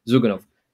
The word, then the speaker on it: Zyuganov: So Zugdani, uh, always get his name wrong, the leader of the Russian Communist Party, mm-hmm. um, Zyuganov, he.